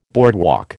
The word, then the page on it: boardwalk: American English